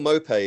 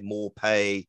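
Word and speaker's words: Maupay: Neil Mopay is another one. He's had everything. He's had Mopay, Morpay, Mopi.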